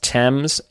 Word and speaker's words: Thames: It's written Thames, but pronounced Thames.